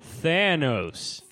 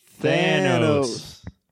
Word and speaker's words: Thanos: Say it with me. Thanos. – Thanos. – 1, 2, 3. – Thanos. – You got it. Okay, so the movie opens. They're on Thor's ship. Thanos has kicked everyone's butt.